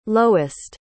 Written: lowest

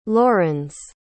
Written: laurens

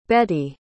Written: Betti

